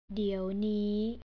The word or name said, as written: diewnii